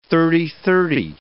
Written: thirty-thirty

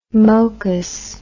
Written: mocus

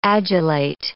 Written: adulate

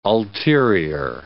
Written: ulterior

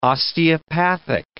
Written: osteopathic

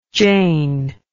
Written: Jayne

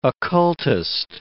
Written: occultist

